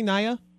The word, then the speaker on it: Naya: It's Naya, right? – Thank you for having me on. – I said it correctly, Naya? – Yes, that's exactly how you pronounce it.